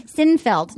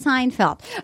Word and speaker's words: Seinfeld: Sinfeld. No, it's Seinfeld.